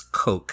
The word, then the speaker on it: Koch: Bobby, I've been saying your last name is Koch. How do you say your last name? – So my last name is Koch. I tell people to pretend you're British and say the word catch, Koch.